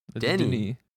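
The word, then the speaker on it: Denis: Is it the first name pronounced like the restaurant or like the menace? – Neither. – Dennis or Denny or Dennis. – I guess it's closer to the restaurant. Denny.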